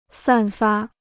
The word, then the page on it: 散发: sàn fā